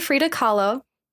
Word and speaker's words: Frida Kahlo: They're a nod to Frida Kahlo.